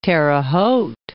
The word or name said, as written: Terre Haute